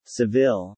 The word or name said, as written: Seville